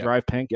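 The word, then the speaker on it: DRV PNK: Is that how you say it? DRVPNK? Yeah. Drive Pink. Yep.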